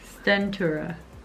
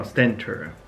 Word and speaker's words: Stentura: Yeah, so we have this Centura Stenograph. Did I say that right? Yeah. Probably didn't. – Stentura. – Oh, Stentura.